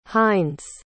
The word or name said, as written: hinds